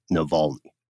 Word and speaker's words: Navalny: So just know that we're aware of it, and the correct pronunciation is indeed Navalny.